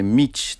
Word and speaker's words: mici: Mici, czy... – Tak, no najczęściej jest to właśnie Mić, tak to powinno się wymawiać.